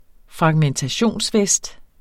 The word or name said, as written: fragmentationsvest